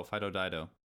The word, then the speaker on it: Fido Dido: In Europe, the mascot for 7-Up was always, I think his name is pronounced Fido Dido. – Oh, Fido Dido.